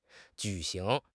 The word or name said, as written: ju3 xing2